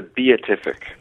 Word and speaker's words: Beatific: Why We Misunderstand the Beatific Vision, is that it? – It's the Beatific.